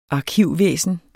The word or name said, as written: arkivvæsen